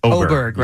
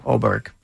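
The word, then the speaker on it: Åberg: I've heard him pronounce it, and it is O. – Oberg.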